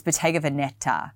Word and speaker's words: Bottega Veneta: Apparently, I actually learnt the other day that I've been saying Bottega Veneta. It's Bottega Veneta. And I've been saying Bottega Veneta for a very long time.